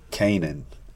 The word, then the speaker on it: Canaan: You, the correct way, not what I'm saying, is the correct way to say the name is Isaiah Canaan.